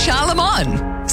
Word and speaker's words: Charlemagne: It's Charlemagne. Say it right.